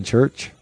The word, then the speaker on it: Chur: He was born in Chur. – In Church? – Chur. – Where's Chur? – Capital city of Graubünden. – Oh, did I know that? Chur, I did.